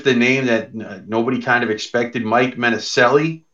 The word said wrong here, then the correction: Mancinelli: It's actually probably pronounced, as I look at it, Mancinelli. I know my ancestors will be a little cranky at me for not saying that right the first time.